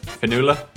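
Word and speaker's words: Fionnuala: This one's pronounced Finula.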